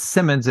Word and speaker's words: Simonds: As Simonds, it's spelled like Simon, so it's Gary R Simonds .com.